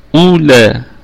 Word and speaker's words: أولئك: أولئك، الكلمة كلها واو التي عليها دائرة مستديرة أو بيضوية، فهذه لا تنطق.